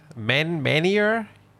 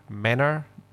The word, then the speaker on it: menhir: Menhir? You know, M-E-N-H-I-R. Menhir? – Menhir.